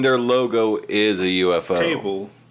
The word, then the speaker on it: ufotable: So, everyone, like, it's always pronounced as ufotable, but I heard a Japanese interview about the movie, like, a while back, and both the interviewer and the person from the studio were saying UFO table.